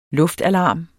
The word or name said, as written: luftalarm